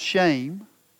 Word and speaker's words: shem: And the word there for name and renown is the Hebrew word shem, which is pronounced shame.